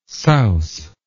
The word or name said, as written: souse